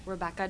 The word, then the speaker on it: Rebecca: Rebecca Dorn, R-E-B-E-C-C-A, last name Dorn, D-O-R-N.